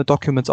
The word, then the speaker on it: DOQMENTZ: Ähm, D-O-Q-M-E-N-T-Z ist da mein Name, Documents ausgesprochen, aber bitte, bitte, bitte nennt mich Aaron.